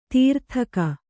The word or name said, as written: ದಾರಿದೆಗೆ